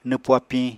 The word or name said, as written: nwpu apiñ